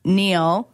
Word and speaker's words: Niall: It's Zayn Neal, which is spelled N-I-A-L-L.